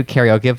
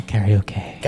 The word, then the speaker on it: karaoke: But when we're in the Midwest, you can come and do karaoke with us, and you'll see how great. – Sorry, I think you mean karaoke. – Karaoke. – Yeah. – And you're still pronouncing it wrong.